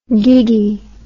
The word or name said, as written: gigi